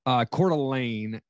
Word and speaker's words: Coeur d'Alene: So, so Coeur d'Alene, is that how you say it then? – Coeur d'Alene. – Coeur d'Alene.